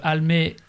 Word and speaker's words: Almé: Almé, comme ça se prononce, avec un E, c'est important.